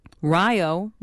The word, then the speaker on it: Rio: Although it's spelled the same as Rio in Brazil, it's pronounced differently because of a typographical error, it turns out. The name of the village was supposed to be Ohio, but the 1860s paperwork was handwritten, and the O and the H were read as an R, thus Rio, rhyming with Ohio.